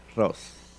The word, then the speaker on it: Rous: Se escribe R-O-U-S, Ross.